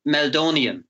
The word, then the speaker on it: Meldonium: He was captain of O'Hanrahan's, who won the intermediate title last year, still their best player, and he tests positive for Meldonium.